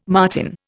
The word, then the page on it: martin: Indian